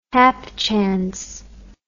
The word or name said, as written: hapchance